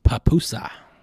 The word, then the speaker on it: pupusa: They've got a world record for an 18-foot pupusa. Make sure you say that right. Pupusa.